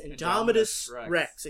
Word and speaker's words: Indominus Rex: There's a part where Chris Pratt, when the Indomitable Rex, Indominus Rex, excuse me, I mispronounced the fake dinosaur's name,